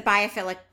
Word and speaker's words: biophilic: I wanted to hit on what you just said, the biophilic design.